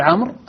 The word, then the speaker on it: عمرو: الواو في عمر والألف في مئة هذه أحرف، وكذلك الألف في التي تذكر بعد واو الجماعة يسمونها الفارقة. – نعم. – هذه كلها أحرف، أولا لا تعد من الجملة من الكلمة التي هي فيها، ولا تنطق، وحتى عند الميزان الصرفي إن من يعرف الميزان الصرفي لا توزن.